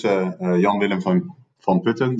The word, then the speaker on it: Jan-Willem van Putten: So my name is Jan-Willem van Putten. That's the Dutch pronunciation.